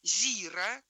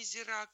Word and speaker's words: зира: Зира, а не зира, как говорят, как говорит большинство.